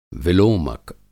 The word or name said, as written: विलोमक